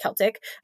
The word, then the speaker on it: Celtic: I feel like I interchanged how it's pronounced, but I'm pretty sure it's pronounced Celtic.